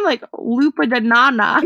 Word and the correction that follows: Nyong'o: No, I always get too self-conscious when I can't pronounce her name, then I just slew it further. – Lupita Nyong'o.